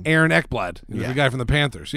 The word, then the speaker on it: Ekblad: is it Austin Ekblad? – You did it.